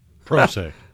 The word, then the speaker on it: pro se: Per se. – Pro se. – Spell it. – P-R-O-S-E.